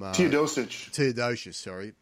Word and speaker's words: Teodosic: I'm going to mispronounce his name, Tedosic from... Teodosic, from Serbia.